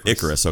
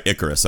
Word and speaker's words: Icarus: Now, this came up because the Netflix documentary Icarus, am I saying that right? – Icarus. – Okay. – Like the boy with the wings made from wax. Oh, okay. All right. Icarus.